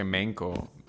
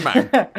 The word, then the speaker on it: Mank: Mank se pronuncia, ¿no? Con A, Mank. – Sí. – Sí, man. – Sí, no Bueno. – creo que se pronuncie Menko. Menk, no. Es Mank.